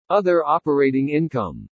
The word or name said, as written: OTHER OPERATING INCOME